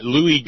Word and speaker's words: Louie: Luis Gohmert? – Oh, I'm sorry. Yeah, Louie Gohmert.